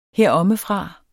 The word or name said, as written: herommefra